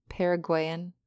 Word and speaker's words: Paraguayan: Paraguayan.